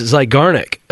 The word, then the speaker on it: Zeigarnik: I think it's Zeigarnik effect. Am I saying that right? – You are.